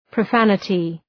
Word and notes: profanity: prə'fænətı

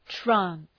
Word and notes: trance: træns

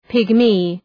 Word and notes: pygmy: ‘pıgmı

